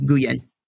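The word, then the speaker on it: Nguyen: So that is David, and then last name is, because the N is silent, it's N-G-U-Y-E-N, Guyen.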